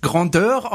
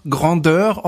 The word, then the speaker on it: Grandeur: Äh, ich bin ja der Meinung, Grandeur ist ein französisches Wort und da ich Franzose bin, muss das eigentlich auch Grandeur of the Seas ausgesprochen werden. Du bist der anderer Meinung. Ich finde, dass Grandeur wunderbar klingt. Siehst du? Aber es sind nun mal Amerikaner, die sagen da Grandeur dazu.